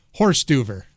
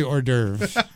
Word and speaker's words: hors d'oeuvre: Ron, your first word is, as I like to say, horse-duver. – What? – Horse-duver. – Horse-duver? – It's really hors d'oeuvre. I know the pronunciation. I just always called it horse-duver.